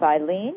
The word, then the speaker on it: Eileen: And my name is Eileen.